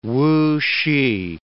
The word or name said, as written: Wusih